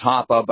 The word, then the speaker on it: Hoppe: Or as Hans Hermann Hoppe might say, his name is actually pronounced Hoppe, but I love it when people mispronounce it.